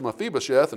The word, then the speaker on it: Mephibosheth: His name was Mephibosheth. Now, a lot of people have problems pronouncing that. Sometimes I do too. But his name was Mephibosheth, and the Bible says that Mephibosheth was a crippled.